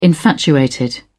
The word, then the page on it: infatuated: British English